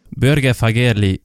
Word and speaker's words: Børge Fagerli: Børge Fagerli, welcome to the podcast. – Thank you. And I must commend you on the pronunciation. That's one of the better ones so far.